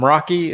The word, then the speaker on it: Meraki: They both work at Cisco, and I am Meraki, is that correct? Mm-hmm.